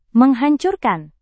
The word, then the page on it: menghancurkan: Indonesian